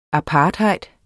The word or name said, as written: apartheid